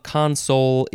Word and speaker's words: console: Second form of console is pronounced console.